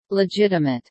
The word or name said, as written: legitimate